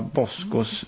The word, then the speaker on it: Bosko: Är det skönt att slippa Boskos vrål? Jag vet inte om jag uttalar det rätt, men Bosko, ja.